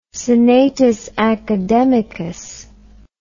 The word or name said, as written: senatus academicus